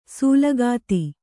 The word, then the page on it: ಸೂಲಗಿತಿ: sūlagiti